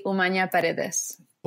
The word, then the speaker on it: Humana-Paredes: Do you want it in English or in Spanish? – Oh, I want it in Spanish. – Okay. It would be Humana-Paredes.